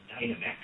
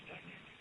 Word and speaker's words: Dynamex: Then, last year, the California Supreme Court, in a case that many on the call, I'm sure, have heard of, Dynamics, sometimes some people call it Dynamex, it looks like it's printed that way, but it's actually pronounced Dynamics.